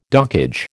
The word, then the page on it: dockage: American English